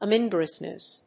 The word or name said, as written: omnivorousness